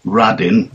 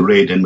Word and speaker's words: Radin: In fact, Kwasowski later goes to work in Tottenham in North London for a Mr. Adin, who I'm almost convinced is Mr. Radin, using the correct sort of ethnic pronunciation, if you like, of that name. So I get this feeling that maybe Abraham Radin, I mean, we pronounce it Radin, but that's an anglicised version of it,